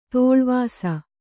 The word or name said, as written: ತೋಳ್ವಾಸ